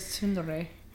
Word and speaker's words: tsundere: I thought that was pronounced. I thought it was tsundere. – I might be pronouncing it wrong. – For once, I was right, and Alyssa was wrong.